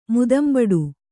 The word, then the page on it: ಮುದಂಬಡು: Kannada